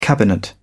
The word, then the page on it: cabinet: British English